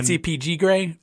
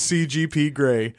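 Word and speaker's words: CGP Grey: You mean CPG Gray? Or PCG Gray? – Brady Horan. No, yeah. His name is CGP Gray. I said it wrong several times.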